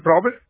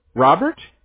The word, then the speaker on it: Robert: In Robert. – Robert? – Yeah, Robert, yeah. – Oh, am I saying that right? Robert? – Yeah, Robert.